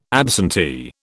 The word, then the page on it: absentee: British English